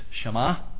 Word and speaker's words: Shema: Most often Gentiles, the E is silent. But anyway, it's Shema. It's Deuteronomy chapter 6, verses 4 to 9.